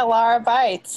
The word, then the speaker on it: Lara Beitz: Yeah, Lara Beitz.